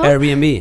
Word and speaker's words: Airbnb: Airbnb.